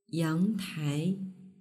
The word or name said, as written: yangtai